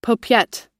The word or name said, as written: paupiette